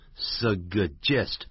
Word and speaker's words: suggest: 像建议常用的是 propose 建议， recommend 建议， suggest，哎，念这个字不要念成 suggest，它有两个 g，所以第一个 g 给旁边，第一个音节叫 sug，第二个呢 gest， suggest，但念慢的是这样念，念快它是顿一下就好了， suggest suggest，不要念 suggest、 yeah.